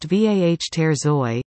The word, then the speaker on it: waterzooi: Waterzooi, pronounced V-A-H ter zooi, is a Belgian dish that was first cooked centuries ago in that country's Flemish region, also called Flanders.